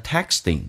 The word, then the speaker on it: texting: 那么这个单词呢 texting, 这个可是其实是不用发音的，因为两个辅音在一起，美语当中只用发后面一个，所以那个可顿一下就行了，叫做 texting and taking photos.